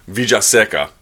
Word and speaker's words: Villaseca: Bellator 147, Thompson versus Villaseca, depending on how you want to pronounce it. I was always taught the two L's in Spanish become a Y, the Y becomes a J sound, so Villaseca.